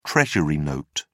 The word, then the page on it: treasury note: British English